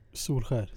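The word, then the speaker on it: Solskjær: Jag kan inte uttala hans efternamn. – Solskjaer. – Solskär. Solskär. Solskär.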